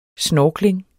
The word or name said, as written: snorkling